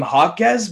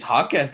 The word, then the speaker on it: Jaquez: The announcer was pronouncing him Hawkes, but it looks like by the last name it's Jax or Jaquez. Maybe it's Hawkes. That's how you pronounce it. That's a really cool way to pronounce it.